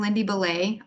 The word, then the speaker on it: Lindy Belley: Well, I'm Lindy Belley, often mispronounced as Lindy Belley. I won't be offended by anyone who happens to call me Lindy Belley.